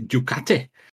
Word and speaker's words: Ducati: No, it should be Ducati.